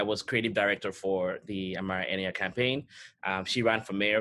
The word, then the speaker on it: Amara Enyia: You worked with a 2019 Chicago mayoral candidate, Amara India, I believe I pronounced that right. – That's correct.